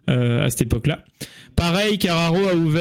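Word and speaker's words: Karacho: Il s'agit de Carraro. Et à l'époque. – Tu veux dire ah. – Alors c'est Carraro que ça se prononce.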